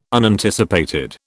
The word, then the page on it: Unanticipated: British English